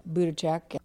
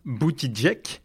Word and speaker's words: Buttigieg: But I also like Buttigieg. – Buttigieg ? Non, on prononce Buttigieg.